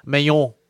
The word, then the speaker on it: Manon: Manion, as would be her French pronunciation of that name.